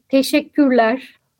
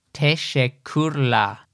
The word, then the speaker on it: Teşekkürler: Teşekkürler. – Wow. – Teşekkürler. – Teşekkürler. – Yes, teşekkür. Very good pronunciation.